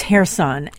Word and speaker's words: Kherson: It's Kherson.